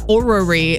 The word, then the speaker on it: Orrery: So this one's hard to pronounce. So it's O-R-R-E-R-Y. – It's Orrery, Courtney.